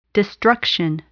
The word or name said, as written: destruction